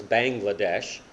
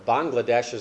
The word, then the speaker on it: Bangladesh: Then we spent 15 years in Bangladesh, which everyone tries to pronounce as Bangladesh. Well, Bangladesh means land of the frogs. Bangladesh means the land of the Bengali people. Bangladesh is the correct pronunciation.